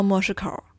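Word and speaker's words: 模式口: 莫市口读作莫市口，写是写作模型的模，公式的市，口袋的口。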